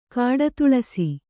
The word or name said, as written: ಕಾಡುತುಳಸಿ